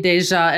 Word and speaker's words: Deja: Andreas Deja. – I think it's actually Deja. At least that's what they, how they pronounce it on that special feature I watched.